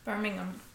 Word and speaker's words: Birmingham: But, Birmingham. Right? How do I pronounce it? – Birmingham.